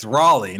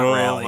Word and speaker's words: Raleigh: Can't even say it right. It's Raleigh, not Raleigh.